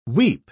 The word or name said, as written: weep